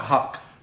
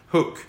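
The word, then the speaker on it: Huq: Is it Hook or Huck? – Huck. – Hook. – It rhymes with fuck.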